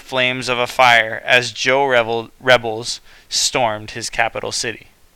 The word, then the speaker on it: Zhou: Around 1045 BCE, a frontier state called Zhou, pronounced Zhou, rose up against the dynasty.